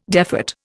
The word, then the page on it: defat: Indian